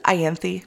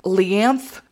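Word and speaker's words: Ianthe: Do you pronounce it Ianthi? – Yeah, that's how I did it. – Because Leanth is how I had to pronounce it, because the font that I was using on the Kindle made it look like an L. I know it's Ianthi now, but Leanth was what it was in my head.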